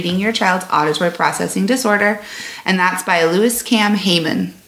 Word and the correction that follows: Lois: And I might have said that wrong. – I feel like it's Lois.